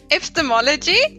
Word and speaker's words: epistemology: And this week we will be talking about, not sure how to pronounce that, epistemology, epistemology.